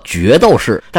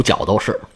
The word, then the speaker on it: 角斗士: 角斗士按照字典上的正音应该读作决斗士，但是这么说我就觉得很别扭，咱们还读角斗士，因为这个词儿呢就来自于这个剑，所以有人把角斗士呢又翻译成剑斗士或者叫斗剑士。